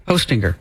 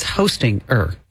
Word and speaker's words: Hostinger: H-O, like Santa, ho, and then stinger. – Hostinger. – Like a bee has a stinger. – Wait, but it's Hostinger. – Yeah, that's just how you spell it. – But it's like hosting and then you put an ER at the end.